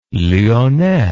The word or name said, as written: lyonnais